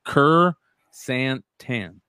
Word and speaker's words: Krrsantan: He said it's Kerr Santan, Kerr Santan.